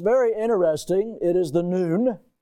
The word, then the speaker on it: Nun: It's the letter Nun. That's how you say it, Nun.